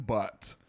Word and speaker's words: Butt: Could it be pronounced Butte? – Nope. B-U-T-T.